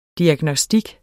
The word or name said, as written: diagnostik